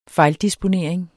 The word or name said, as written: fejldisponering